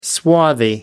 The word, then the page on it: swarthy: American English